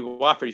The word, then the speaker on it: Wofford: W-O-F-F-O-R-D. It's pronounced Wofford.